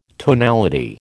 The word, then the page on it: tonality: American English